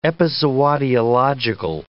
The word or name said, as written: epizootiological